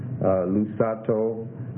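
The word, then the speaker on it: lusato (λυσάτω): Number nine, third person singular aorist active imperative, lusato, lambda, upsilon, sigma, alpha, tau, omega. Lambda, upsilon, sigma, alpha, tau, omega. Stress on the alpha there.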